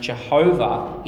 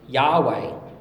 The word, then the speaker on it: Yahweh: In 1520, a guy named Gallatinus worked out that the Hebrew word for God's name would be pronounced Jehovah in English. But modern scholars have actually worked out it should be pronounced Yahweh.